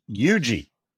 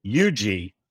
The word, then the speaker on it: Huger: The most important part, which is, according to the state, the newspaper in Columbia, South Carolina, it is pronounced UG, like Y-O-U, emphasis on the first syllable, UG, South Carolina.